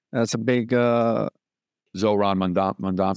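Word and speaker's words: Zoran Mamdani: Well, just so like, how do you say his name? Soran, his win as well. That's a big, Zoran Mondami. – Zoran Mondami. Yeah. I was like, Sauron.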